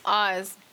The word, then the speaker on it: Oaz: Oz. – Oz? – How you spell that? – Like Wizard Oz? O-A-Z. – I like that. – O-A-Z? – Oz?